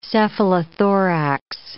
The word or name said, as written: cephalothorax